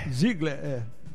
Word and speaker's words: Ziegler: Não, é porque a pronúncia é Ziegler. – Z-I-E-G-L-E-R, mas a pronúncia é Ziegler, que é de origem alemã.